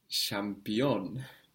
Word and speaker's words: şampiyon: The word for champion in Turkish is şampiyon. Şampiyon. The ç becomes ş. – Champion. – Champion. And this sh is spelled with an S, with a little tail on the S.